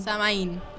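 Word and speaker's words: Samhain: Eh, ellos celebraban un festival llamado Samain. Samain. Samain. ahí está, lo pronuncié.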